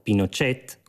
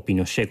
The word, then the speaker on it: Pinochet: L'esperimento della via cilena al socialismo, come viene battezzata dallo stesso Allende, si conclude l'11 settembre quando i militari capeggiati da Augusto Pinochet, come lo pronunciano i cileni, o Pinochet come siamo abituati a pronunciarlo, bombardano il Palazzo della Moneda, la sede del Palazzo Presidenziale, ed Allende viene ucciso o si suicida.